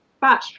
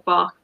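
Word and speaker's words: Bach: So the batch flower remedies, or you can call them the bark flower remedies, people, they're interchangeable, neither one or other is, so I sometimes use both pronunciations.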